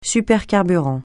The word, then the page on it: supercarburant: francais